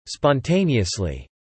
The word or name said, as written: spontaneously